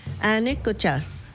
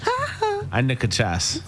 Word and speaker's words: Anikuchas: Anikuchas. – Hey, you got it. It's a squirrel. – Did I say it right? – You said it perfect. Anikuchas.